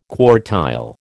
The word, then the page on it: quartile: American English